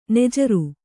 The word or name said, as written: ನಾಜರು